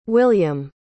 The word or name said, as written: william